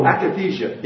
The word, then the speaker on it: akathisia: There's a word that was kicked around that I'm not that familiar with, but this a cathesia. The reaction. – Oh, akathisia, yes.